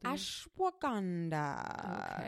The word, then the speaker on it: Ashwagandha: Ashwagandha.